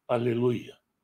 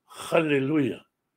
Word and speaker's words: аллилуйя: Снова в который раз скажу, это, наверное, важно говорить постоянно, что есть люди, добрые люди, которые очень хотят говорить правильно, и поэтому говорят «халлилуйя». Слово «халлилю» означает «позорьте», «порочьте», имеет прямо противоположное значение. То есть, если вместо «аллилю» мы говорим «халлилю», то говорим обратное тому, что хотим сказать, и нужно быть очень внимательным с этим.